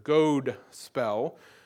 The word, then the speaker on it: godspell: And it's a long O sound here, godspell.